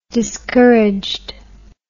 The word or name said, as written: discouraged